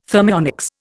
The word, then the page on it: thermionics: Indian